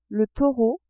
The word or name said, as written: taureau